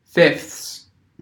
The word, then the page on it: fifths: American English